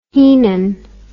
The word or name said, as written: heenan